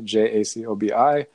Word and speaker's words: Jacobi: Jung, Yolanda Jacobi, Jacobi is how they pronounce it, actually, Jacobi.